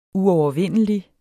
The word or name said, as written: uovervindelig